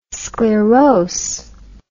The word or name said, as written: sclerose